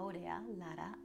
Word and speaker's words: Aurea Lara: Aurea Lara, A-U-R-E-A-L-A-R-A. I never know how to pronounce my name properly because I'm like in between the Spanish pronunciation, the English pronunciation, and I get a little wonky sometimes. And of course, you can also find me on YouTube, Aurea Lara, A-U-R-E-A-L-A-R-A.